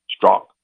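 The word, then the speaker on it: Strzok: But anyway, this guy Strock. I'm sorry. Okay, Strock. I got the people over here pointing at me that it's a short O, not a long O. Strock was on there and pretty smug.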